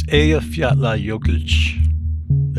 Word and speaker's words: Eyjafjallajökull: And I'm not going to get this name right, but it's Eyjafjallajökull. – Eyjafjallajökull. – Eyjafjallajökull. – Eyjafjallajökull. Am I pronouncing that correctly? – Nearly. – It's Eyjafjallajökull. There you go.